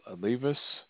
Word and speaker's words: Levis: I'm not sure how you pronounce his last name. Levis. So it's Levis. Will Levis.